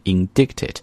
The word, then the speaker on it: indict: 这个词我告诉大家，以前我也这个摔过这个坑啊，以前就把它读成了一个大白字，读成了 indicted，因为如果你按字母去读，它的确是这个 indicted。 However，这个单词读就应该读 indict。在这里呢，这个 c 不发音，而这个 i 呢要发 i 这个声音，所以读的时候是 indicted。